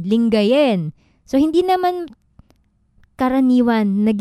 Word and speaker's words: Lingayen: Sa kakakaiba yung pag-pronounce nga nung E sound, ganyan. So, maswa siya. So, Bimaloy, ayan, Lingayon.